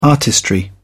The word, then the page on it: artistry: British English